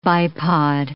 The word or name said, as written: bipod